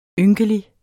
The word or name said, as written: ynkelig